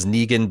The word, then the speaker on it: Negan: It's been a wild 6 months binging you guys, from laughing at Dying on the Hill that was Negan being pronounced like Megan, to saying that you...